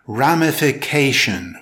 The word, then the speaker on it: ramification: It's pronounced ramification with stress on the 4th syllable.